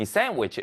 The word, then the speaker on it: sandwich: 자, 그런데 이게 있는 건 좋은데 우리 친구들 이 sandwich 읽을 때 D는 묵음입니다. sandwich라고 읽지 않습니다. sandwich라고 있습니다. sandwich. 그렇죠. D 발음은 없다 생각하면 되겠습니다.